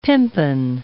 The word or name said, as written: pimping